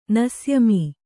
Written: ನಸ್ಯಮೀ

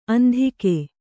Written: ಅಂಧಿಕೆ

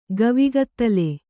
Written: ಗವಿಗತ್ತಲೆ

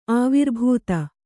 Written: ಆವಿರ್ಭೂತ

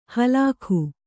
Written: ಹಲ್ಕ

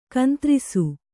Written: ಕಂತ್ರಿಸು

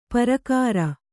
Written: ಪ್ರಾಕಾರ

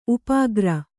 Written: ಉಪಕಾರ

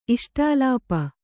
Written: ಇಷ್ಟಾಲಾಪ

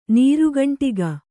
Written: ನೀರುಗಂಟಿಗ

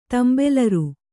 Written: ತೆಂಬೆಲರ್

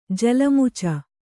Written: ಜಲಮುಚ

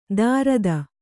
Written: ದಾರದ

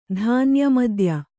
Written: ಧಾನ್ಯಮದ್ಯ

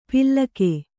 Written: ಪಿಲ್ಲಕೆ

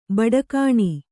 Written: ಬುಡಿಗಾನೆ

